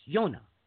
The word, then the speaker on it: Yonah: Well, it's interesting that you say that because, of course, the word for dove is Yonah. Which, of course, in the English would be Jonah, is it not? – That's exactly what it is. It's Yonah, yes. We pronounce that Jonah.